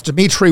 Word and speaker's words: Dimitri: That's Dimitri with three I's, D-I-M-I-T-R-I.